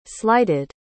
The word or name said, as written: slighted